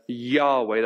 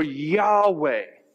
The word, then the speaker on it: Yahweh: Some people pronounce that as Yahweh. That's what we think, kind of. We try to add into, kind of go, this is probably what it was. So what Isaiah is saying is, prepare the way for Yahweh.